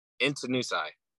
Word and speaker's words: Intanusai: Intanusai. – Intanusai. That's easy. I don't know why. – The length of it makes it look difficult, but it's pretty much how it's spelled out.